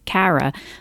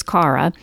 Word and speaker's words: Cara: The other interesting thing is that uh, Cara, and I keep I, forgive me, I'm a Philly girl, so I'm mispronouncing her name as Cara, but it is Cara.